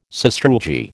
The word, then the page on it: sastrugi: American English